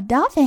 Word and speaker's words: dolphin: Yes, a dolphin has a mother, just like me and you. 好，那么这个地方要注意海豚它的发音。那么 o 发的是短音 o，那么 l 发的是它的模糊音，卷舌不发出来。